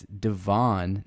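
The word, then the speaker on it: De'Von: It's uh, I looked it up. Texas A&M's pronunciation guide. It's Devon, not Devon.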